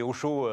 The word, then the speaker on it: Hosho: Alors, il y, a plein d'innovations dans tous les sens, donc euh, on n'a pas le temps, mais chaud c'est quoi chaud Alors, chaud c'est Ocho, euh, au chaud sauf que ça s'écrit H-O-S-H-O.